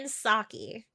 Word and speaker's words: Psaki: Jen Psaki. The P is silent.